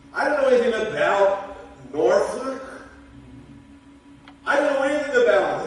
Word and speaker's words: Norfolk: Norfolk is how the locals say it.